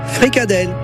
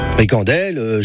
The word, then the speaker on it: fricadelle: La bonne prononciation francophone en France ou en Wallonie, c'est fricadelle. Fricandelle chez les flahutes.